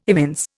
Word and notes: evince: Indian

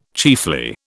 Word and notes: chiefly: British English

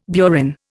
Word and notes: burin: Indian

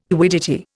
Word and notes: quiddity: Indian

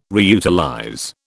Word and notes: reutilize: British English

